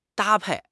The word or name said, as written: 搭配